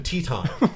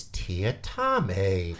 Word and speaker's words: Tiatime: His name is Tiatame. – Huh. – But it's spelled Tiatime. So everyone calls him T-Time. And he's always like, "It's Tiatame."